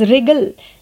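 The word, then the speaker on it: wriggle: रिगल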